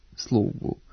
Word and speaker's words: slugu: Kako se slog ispred ovog nastavka u genitivu množine po pravilu ne duži, prema Stevanovićevoj gramatici i rečniku srpskoga jezika Matice Srpske, ove oblike trebalo bi izgovarati sa kratkouzlaznim akcentom, dakle, mnogo, ruku, nogu, slugu, uz dozvoljen dubletni oblik sluga sa dugouzlaznim akcentom.